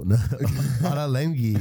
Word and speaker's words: Ottolenghi: It's a London-based chef, Yotam Ottolenghi. That's a really hard name to pronounce. – Let me see this. Yotam Olatengo. Ottolenghi. – Ottolenghi or something like that.